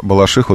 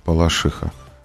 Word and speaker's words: Балашиха: Словарь «Дарья Резенталь», слово «балашиха», ударение на букву «и». Балашиха. А вы делаете на букву «а». Балашиха.